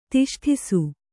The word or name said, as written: ತಿಷ್ಠಿಸು